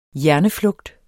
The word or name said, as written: hjerneflugt